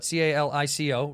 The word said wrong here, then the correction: Calcio Storico: Calcio Storico.